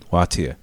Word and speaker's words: Wātea: Yeah, Wātea. How do you spell that? – W-A-A-T-E-A. To the untrained ear or eye, it would look like Wātea. Like tea? Like you're just an old tea. Wātea.